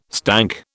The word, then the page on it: stank: British English